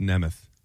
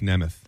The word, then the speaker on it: Nemeth: It's pronounced Nemeth. Nick Nemeth.